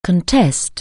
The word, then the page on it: contest: anglais